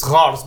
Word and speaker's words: Kars: It's Treaty of, it's Kars, but it's spelled K-A-R-S.